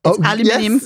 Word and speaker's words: aluminium: She also does it with aluminum foil balls. I said it right. Aluminum is the way they say it, isn't it? – Aluminium. Yes, thank you. Exactly.